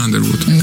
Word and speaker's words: Underwood: Ну, она как произносится в Америке, она произносится Underwood, конечно, по правилам произношения. А в России писали уже русскую транскрипцию, писали Underwood, ну, через букву У первую.